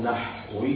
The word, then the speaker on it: نحوي: وأيضا نقول فلان فرضي، أنا فلان نحوي، التحريك خطأ، الصواب نحوي بالتسكين، لأنه نحو بالتسكين، تسكين وسط.